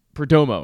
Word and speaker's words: Perdomo: I mean, Geraldo Peromo, uh, Perdomo, excuse me, is not a big name in Major League Baseball.